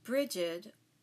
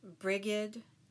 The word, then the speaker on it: Brigid: Brigid can be pronounced many different ways. Brigid, Breed, among the more popular. I'll stick with Brigid.